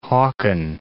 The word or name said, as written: Haakon VII